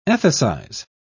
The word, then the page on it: ethicize: British English